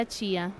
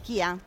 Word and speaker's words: chia: Quindi quelli che noi in Italia chiamiamo semi di chia? Esattamente. Così? Okay. Sì. In realtà si pronuncia Chia, allora Chia.